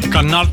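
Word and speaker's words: Qana: 102 Libanezen komen om als Israël een VN-opslagplaats in Cannes beschiet. Cannes, zo moet ik het goed uitspreken.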